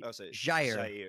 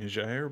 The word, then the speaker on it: Jair: the Brazilian J in the Brazilian Portuguese J sounds like the S in pleasure. So it'd be Jair Bolsonaro. So it's kind of a half J, or it's a soft J, I guess you would say.